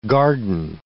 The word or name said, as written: garden